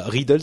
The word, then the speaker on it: Readdle: Il y a Riddle, ça s'écrit R-E-A-D-D-L-E.